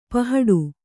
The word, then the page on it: ಪಹಡು: pahaḍu